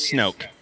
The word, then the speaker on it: Snoke: My last name legitimately is Snoke. – Isn't that awesome? – Same spelling, same pronunciation.